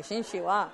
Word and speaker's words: Qin Shi Huang: Xin Shi Huang? What? Did I say it right? Yes. Can you say it? Xin Shi Huang. Okay, Xin Shi Huang.